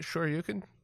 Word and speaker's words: Shoryuken: Shoryuken? – Yeah, like the Street Fighter move, although if you want to scream it, that's okay.